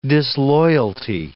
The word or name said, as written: disloyalty